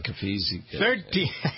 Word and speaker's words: Caviezel: Caviezel. – God bless you. – Caviezel.